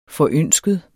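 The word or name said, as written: FORØNSKET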